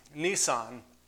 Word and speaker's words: Nisan: that month is called Nisan, pronounced just like the car.